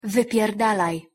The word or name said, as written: wypierdalaj